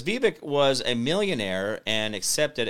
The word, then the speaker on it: Vivek: Ann says Vivek is pronounced Vivek, like the snake.